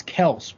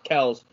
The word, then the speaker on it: Kelce: It's Kels pronounced, technically. It's... – Yeah, Travis Kelce did an interview and said it's pronounced Kels, and Jason Kelce's like, yeah, but...